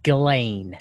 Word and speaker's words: Ghislaine: What's the proper pronunciation? What did they tell you? – My belief is... people have told me it's Ghislaine Maxwell.